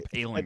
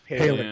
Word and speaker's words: Poehling: That's what Ryan Poehling is here for. Paling? Sarah Palin, I think that's how you pronounce that name. It's Ryan Paling. – I think it's Paling. Yeah.